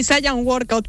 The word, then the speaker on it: Saiyan Workout: A ver, primero dime si lo he dicho bien, porque lo mismo te estoy presentando fatal. – Sí, Saiyan Workout, pero lo puedes pronunciar como tú quieras.